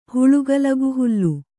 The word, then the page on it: ಹುಳುಗಲಗುಹುಲ್ಲು: kannada